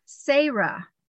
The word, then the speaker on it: Seryah: I believe, S-E-R-Y-A-H, or I would need to look what my notes were, but she goes, but it's pronounced like this, Sarah.